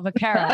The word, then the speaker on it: Vaccaro: Did I say it right? – No, Vaccaro.